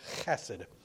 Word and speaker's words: chesed: It's the Hebrew word chesed. Technically, you got to pronounce it with that guttural deep thing and in the voice.